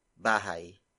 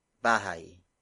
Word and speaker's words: bahay: In this case, it's bahay. Bahay.